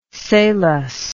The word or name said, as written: salus